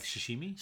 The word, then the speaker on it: sashimi: A shishumi? Is that a fish for... raw fish for... – Is that like sashimi? – Sashimi, that's it. Sorry, I wrote it down somewhere and I forgot to say it properly.